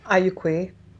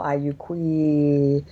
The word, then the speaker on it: Ayukwe: Yeah, Ayukwe. It's pretty common greeting, and it's basically saying hello. And as you, if you drag out the last syllable, Ayukwe, that widens the expression